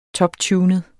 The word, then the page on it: toptunet: -ˌtjuːnəð; -ˌtuːnəð